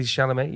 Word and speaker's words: Chalamet: Do you know Timothy Chalamet? – Is that how you say his name? – Timothy Chalamet.